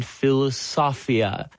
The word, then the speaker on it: philosophia: I guess the last term we have here to look at is the one you've mentioned, philosophia. How would you pronounce it? – Philosophia, it's obviously Greek.